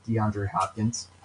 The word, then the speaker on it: DeAndre Hopkins: I don't have faith in any of the wide receivers not named DeAndre Hopkins.